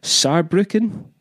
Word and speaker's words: Saarbrücken: And then in Germany, we're playing in Cologne, Hamburg, Berlin, Munich, Saarbrücken. Yeah. Am I saying that right? Yeah. yeah. V- yeah.